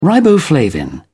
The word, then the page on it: riboflavin: British English